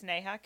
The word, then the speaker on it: Neha: It's Neha Kachari.